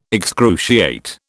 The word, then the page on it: excruciate: British English